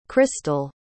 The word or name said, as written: cristal